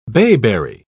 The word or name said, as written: bayberry